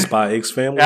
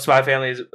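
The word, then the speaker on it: Spy × Family: Okay, I was about to, this man made a joke earlier about pronouncing the X. We don't do that here. – You mean Spy X Family? – Oh, damn it, he did it.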